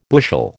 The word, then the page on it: bushel: American English